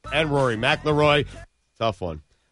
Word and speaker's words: McIlroy: You say McElroy.